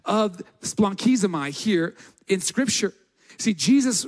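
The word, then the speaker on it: splanchnizomai: The Greek word right here used for felt compassion is splanknizomai. That's how you pronounce it, literally the hardest word in Greek.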